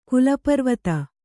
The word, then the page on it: ಕುಲಪರ್ವತ: Kannada